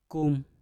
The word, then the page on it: kum: Mpur